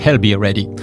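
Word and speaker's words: Ailbhe Reddy: A-I-L-B-H-E et plus loin R-E-D-D-Y. – Bon, donc, ça se prononce comme ça. – I'll Be Ready.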